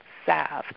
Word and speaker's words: salve: I have a new, I have a friend that does, these salve, but I think it's called salve. Enunciated without the L, but it's spelled S-A-L-V-E.